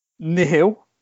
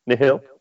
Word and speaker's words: Nihil: so I'm going to try and pronounce it. It's Nihil. – Nihil, yes. Nihil. – However, if you listen to, I think it's the Star Wars YouTube page, they call it Nihil. And I'm like, no, it's Nihil. We agree that it's Nihil, but nevertheless, it's just the pronunciation of the villains.